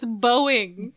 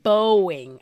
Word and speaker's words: Boeing: It's Boeing. Boeing. Okay. Like a bow and arrow. Okay. – Yeah. But it's spelled more like boing.